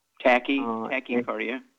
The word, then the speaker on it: tachycardia: In 2004, he was diagnosed with idiopathic congestive heart failure, and he has, I hope I said it correctly, tachycardia. – Tachycardia.